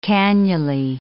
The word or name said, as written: cannulae